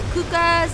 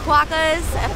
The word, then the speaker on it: quokkas: We're going snorkeling and we're seeing the kookas. I never know how to say their names. Quokkas? Quokkas, apparently they're like a squirrel.